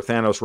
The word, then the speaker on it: Thanos: It's like, remember, Thanos rhymes with Davos.